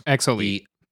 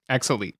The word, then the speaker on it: X Elite: X-Elite. – The X-Elite.